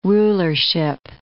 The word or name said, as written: rulership